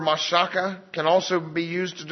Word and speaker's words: mashach: It's spelled M-A-S-H-A-C-H, and it's pronounced mashaka. I'm sorry, mashaka.